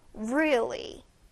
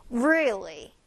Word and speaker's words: really: 자, really 발음 중요합니다. 아주 자주 쓰는 단어니까요. 선생님 따라해 주세요. will 아니고요. real 아닙니다. will과 real 그 사이에 어딘가인데 약간 real로 시작하되 입을 우로 모아주세요. really 한 번 더 really 자, she's really good at painting.